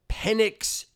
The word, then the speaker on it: Penix: And Michael Penix Jr., did I get his name right? – Yep.